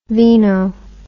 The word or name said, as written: veeno